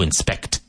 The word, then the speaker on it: inspect: With the emphasis on the second syllable. To inspect.